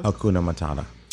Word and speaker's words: Hakuna Matata: Hakuna Matata? – Hakuna Matata.